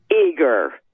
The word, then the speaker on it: Uyghur: How do you know it's Uyghur? – Uyghur. – W. U-Y-G-H-E-R. You are. – Yeah. It's usually pronounced Uyghur. Uyghurs.